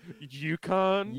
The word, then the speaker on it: Yukon: The UK. – And for a second, I was just like, what? Is this motherfucker serious right now? – No. – It's pronounced Yukon.